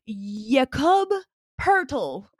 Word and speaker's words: Jakob Poeltl: So I Googled how to pronounce his name, and it's Jakob Poeltl.